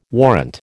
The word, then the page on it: warrant: American English